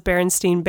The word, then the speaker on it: Berenstain: Like, a ton of people my age remember that it was Berenstain Bears. Like, but everybody, if you look at the cover, it's spelled with an A. And it's Berenstain Bears.